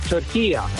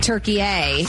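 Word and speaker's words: Türkiye: At the end is a short A. It's not a long A. In the Arabic language, it's called turkiya. So you could say turkiya. – You and I are making it a hard A. Turkey A, and it's turkiya.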